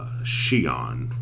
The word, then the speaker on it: Xi'an: Specifically, I was in Xi'an, which mm-hmm. is spelled X-I-A-N.